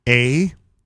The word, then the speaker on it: aholehole: A-H-O-L-E-H-O-L-E. And A-hola. – Here's how it's pronounced. – A-hole, a-hole. – Ahole-hole. – Ahole-hole.